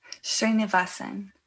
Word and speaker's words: Srinivasan: It is very complex. Most Americans don't get it. Most Indians don't get it. So it's it's Srinivasan, which isn't the most easiest name to pronounce, but it's Srinivasan.